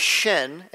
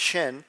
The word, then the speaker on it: Shinn: So our name, our last name is Shin, and it's pronounced that way, even in Korean, it's Shin.